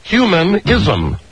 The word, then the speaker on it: humanism: The Renaissance, the rebirth of humanicism. – I don't know how to pronounce it. – No. Spell it. H-U-M-A-N, humanism. – Humanism.